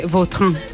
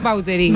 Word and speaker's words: Vautrin: Vautrin. Bueno. Pero aquí decimos bueno, Bauterín. – Claro, sí, sí. ¿No? Pues, es que si me pregunta exactamente cómo se pronuncia, pues así. Claro, pero sí. Yo ya soy Bauterín. – Ya. – Está españolizado total.